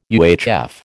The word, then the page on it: UHF: American English